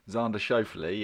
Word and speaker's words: Xander Schauffele: Xander Schauffele. – I said that right. – Xander Schauffele, yeah.